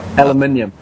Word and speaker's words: aluminium: They are first-class aluminum. Is that? Am I saying it right? – Aluminium.